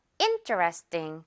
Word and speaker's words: interesting: 그런데 interesting 이렇게 발음 안 하니까요. in에 강세 있어서 interesting. 마지막 부분 강세 없어요. 그래서 ting보다는 띵이 되는 거죠.